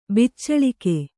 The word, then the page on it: ಬಿಚ್ಚಳಿಕೆ: biccaḷike